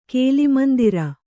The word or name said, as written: ಕೇಲಿಮಂದಿರ